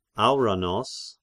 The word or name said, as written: Auranos